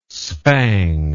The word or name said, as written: spang